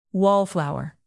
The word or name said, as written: wallflower